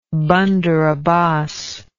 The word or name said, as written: bandar abbas